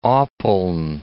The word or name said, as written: Oppeln